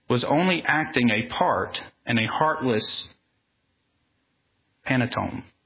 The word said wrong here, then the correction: pantomime: Pantomi. Anybody pronounce that one? Pantomime, thank you.